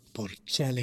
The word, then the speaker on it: Bocelli: Andre Bocelli. – Did I say that right? I don't know, but it sounded good to me. – Okay. Bocelli.